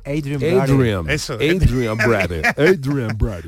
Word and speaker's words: Adrien Brody: Adrien Brody.